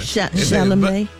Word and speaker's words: Chalamet: Now, they said, honey, Rihanna and A$AP Rocky were also spotted, y'all, at the match, honey, and they said Timothy Chalamet and, honey, Kylie Jenner. – Chalamet. – Oh, that's how you say it.